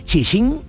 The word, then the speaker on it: Cixin: Sostener el cielo de Xing o sea, se escribe C-I-X-I-N, Chisin Liu.